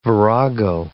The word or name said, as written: virago